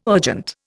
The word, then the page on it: urgent: Indian